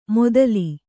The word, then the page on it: ಮೊದಲಿ: modali